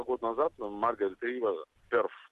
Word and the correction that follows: Перт: такой город есть, если я правильно его произношу. – Можно его произносить как Перт.